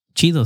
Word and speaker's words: Chido: You can follow me at Cheeto Jerry too. – How do I spell that? – Cheeto, C-H-I-D-O.